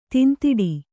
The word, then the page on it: ತೂಂತಿಡು: Kannada